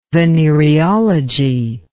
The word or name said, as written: venereology